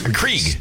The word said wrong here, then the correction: Keurig: Krieg. – Keurig. – Yeah. Keurig. Keurig.